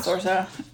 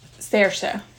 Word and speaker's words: Saoirse: But it's like that lady, that actress, her name is spelled weird, but it's pronounced Saoirse. – I don't know who that is. Not Saoirse. Saoirse.